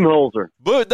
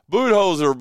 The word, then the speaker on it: Budenholzer: Budenholzer. – There you go, Budenholzer.